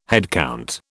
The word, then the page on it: headcount: British English